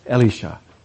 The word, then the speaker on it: Elisha: Because the names sound so much alike, I'm using the cool, went to seminary, pronounce it like Hebrew school, Elisha.